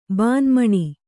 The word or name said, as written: ಬಾನ್ಮಣಿ